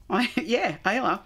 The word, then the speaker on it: Islay: Now, despite only having around 3,000 residents, Islay, it's funny. I do it two ways. – Right. – Islay has more than a dozen working whiskey distilleries and is often called Whiskey Island.